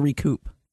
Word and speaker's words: recoup: Yeah, I'll recoup the money. – I think it's recoup. – I don't believe you pronounced the final P.